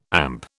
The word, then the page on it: AMP: British English